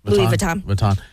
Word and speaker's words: Louis Vuitton: Louis Vuitton.